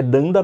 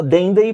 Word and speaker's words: Danda: Que é Danda na pronúncia correta, né? nativa e ele fala Dendey porque ele fala é em inglês.